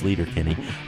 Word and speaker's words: Sleater-Kinney: This apparently is the correct pronunciation for the off-ramp in Olympia on the highway that originally gave the group its name. We've been saying it wrong for 20 years, Sleater Kinney.